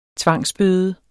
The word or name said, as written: tvangsbøde